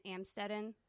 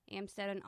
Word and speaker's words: Amstetten: Elizabeth Fritzl was born on April 6th, 1966, to Joseph and Rosemary Fritzl in Amstetten, I believe is how it's said, Amstetten, Austria.